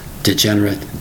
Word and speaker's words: degenerate: Degenerate, degenerate, degenerate. Spelled D-E-G-E-N-E-R-A-T-E. The last part is pronounced like you had an I-T there. Degenerate, degenerate. Sometimes people get angry at somebody else.